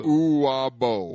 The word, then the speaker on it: Uwabo: Uwabu. – Uwabu. – Uwabo. – Uwabo. – U-W-A-B-O.